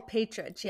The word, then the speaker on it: Partridge: Oh, Partridge, yeah.